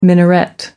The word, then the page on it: minaret: American English